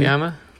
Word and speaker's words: Sciamma: the follow-up by Céline Sciamma. Am I pronouncing that correctly? – Sciamma.